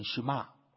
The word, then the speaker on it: Shema: 好，这个呢，希伯来文呢就读成 Shema。